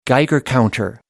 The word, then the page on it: geiger counter: American English